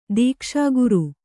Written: ದೀಕ್ಷಾಗುರು

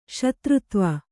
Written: ಶೂದ್ರತ್ವ

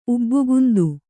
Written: ಉಬ್ಬುಗುಂದು

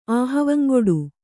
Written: ಆಹವಂಗೊಡು

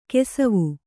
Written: ಕುಸುವು

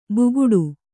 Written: ಬುಗುಟು

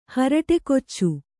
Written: ಹರಟೆಕೊಚ್ಚು

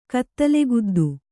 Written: ಕತ್ತಲೆಗುದ್ದು

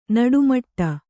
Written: ನಡೆಮುಡಿ

